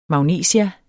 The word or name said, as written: magnesia